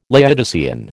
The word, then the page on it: Laodicean: American English